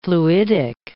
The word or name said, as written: fluidic